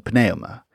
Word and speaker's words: pneuma: Over time, English dropped the F, just as we don't pronounce the P in pneuma.